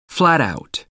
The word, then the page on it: flat out: American English